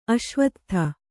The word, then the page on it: ಅಶ್ವತ್ಥ: aśvattha